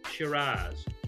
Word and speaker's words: Shiraz: In fact, when people think of Shiraz or Syrah, as it's often pronounced by the French, the South Africans, the New Zealanders, uh, and many other parts of the world, the Californians pronounce it as Syrah, but in Australia we call it Shiraz.